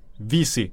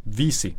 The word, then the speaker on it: Vesey: Och du uttalar det Vesey. Du säger det så. – Ja, jag som har noll förtroende trovärdighet när det kommer till uttal har nu fått lära mig, satt på plats, att han heter absolut inte Jimmy Vesey eller Jimmy Vesey.